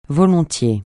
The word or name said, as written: volontiers